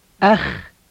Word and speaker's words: أخ: أخ أخ أخ. – Now say it, and don't be afraid to say the scraping K-like sound at the end of the word.